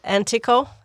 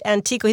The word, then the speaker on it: Antico: And our other guest is Pete Antico. Did I pronounce that correctly? – Antico, yeah. – Pete Antico.